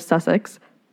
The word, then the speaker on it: Sussex: I live somewhere in England, a small village community just outside of Sussex." I'm saying that right? – Correct. – I looked it up, but I wanted to make sure.